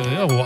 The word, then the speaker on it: Ferro: Wieso habe ich das richtig ausgesprochen? – Ferro, aber nee, Ferro heißt das. Da hast du vollkommen korrekt ausgesprochen.